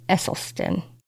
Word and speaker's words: Esselstyn: I ran across Rich Roll and Rip, I believe his last name is pronounced Esselstyn, both triathletes and authors raised in Texas.